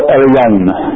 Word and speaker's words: Elyon: This is the way I pronounce it. There are probably two or three different pronunciations, and if I spoke Hebrew at any worth, it would probably sound a little different. I always have a heavy accent. I know when I speak Spanish, my wife about dies because of my accent, so this is my English version of the Hebrew word, pronounced Elyon.